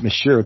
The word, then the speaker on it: Monsieur: God damn it, it's Monsieur. The N is silent. All N's are silent in Frost.